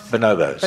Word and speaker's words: bonobos: So basically, this is looking at bonobos. Am I saying that right? Or bonobos? – Bonobos.